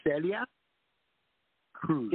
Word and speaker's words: Celia Cruz: Celia Cruz.